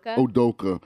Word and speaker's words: Udoka: Udoka.